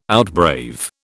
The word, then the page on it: outbrave: British English